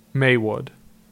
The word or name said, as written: Maywood